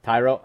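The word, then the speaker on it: Thairo: So I got, I might be mispronouncing his first name, but Tyro Estrada for the Giants. – Right. – Tyro, I think I might have got it right. – You're right. No, you're right.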